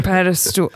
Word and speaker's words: pedestal: Pedestool, though, I could not let something that appalling slide. – Pedestal. How do you say it again? – Pedestal.